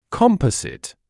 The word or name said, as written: composite